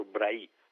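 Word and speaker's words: Bray: e quindi la pronuncia è senz'altro Brahi, come del resto preferisce lo stesso ministro, l'ho letto nel suo blog.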